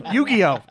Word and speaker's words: Yu-Gi-Oh!: Yu-Gi-Oh!